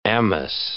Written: amice

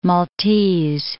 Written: Maltese Islands

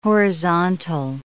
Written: horizontal

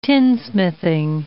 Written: tinsmithing

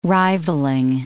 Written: rivalling

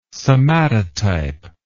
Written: somatotype

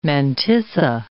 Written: mantissa

